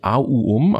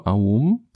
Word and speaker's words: Om: Und Om ist ganz spannend, weil Om heißt eigentlich Auum, also drei Silben Aum.